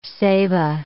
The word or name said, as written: ceiba